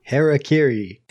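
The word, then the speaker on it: hara-kiri: That scene where he stabs himself and he's doing the Harakiri. I'm going to say that wrong the whole time. – Harakiri.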